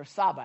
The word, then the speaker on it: Tsabaoth: But anyway, and to me, and it says, when it says the Lord Almighty, it's a Hebrew word right here, and it's Jehovah Tsabaoth, or Saba, actually, the T is silent.